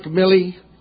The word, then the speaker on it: Milley: We're here to welcome our outstanding new chairman of the Joint Chiefs of Staff, General Mark Milley.